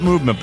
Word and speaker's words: MVMT: We call it Movement, but that's the way you spell it.